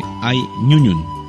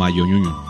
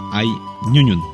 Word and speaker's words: ay ñuñun: Se dice ay ñuñun, no ayoñuñun. Se dice ay ñuñun.